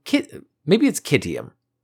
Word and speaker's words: Citium: Zeno of Citium was a Greek philosopher from Kittium. Kittian? It has a pronunciation that is, it's spelled C-I-T-I-U-M, but the pronunciation they have is K-I-T-I-O-N. – Yeah, which is not the same.